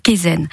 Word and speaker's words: Kaizen: Kaizen, comment ça se prononce? – Tout à fait, c'est la méthode Kaizen.